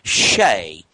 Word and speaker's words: Xe: Well, Monsanto has a contract with a company called Shea, which is spelled XE.